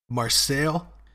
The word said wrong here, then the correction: Marseille: Marseille. Very close.